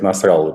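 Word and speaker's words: Насралла: так и устами лидера Хизбаллы Хасана Насралы, подчерк Насралы, э, прошу прощения, меня уже указывали специалисты, арабисты, что именно так надо ставить ударение, э, говорит, что как-то лучше не становится вообще, честно говоря, от ударения Я прошу прощения, да.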